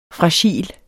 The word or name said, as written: fragil